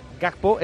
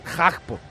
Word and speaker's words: Gakpo: Por ejemplo, Gakpo es Hagpo. – Hagpo. – O sea, allí la G suave no la pronuncian.